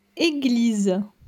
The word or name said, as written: Eglise